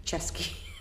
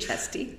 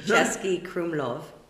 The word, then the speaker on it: Český Krumlov: Chesky. Do you think Chesty? Český Krumlov.